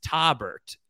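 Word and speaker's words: Tobert: And you do pronounce that second T. It's not Taubert. Yeah. It's not like a cheese. It's Taubert is his name.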